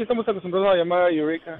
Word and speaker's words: Eureka: Se escribe Eureka, sí. Y se pronuncia Eureka.